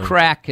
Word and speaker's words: craic: Crack is what an Irish session's all about. That means good fun. So we should say that's spelled C-R-A-I-C? – Yes.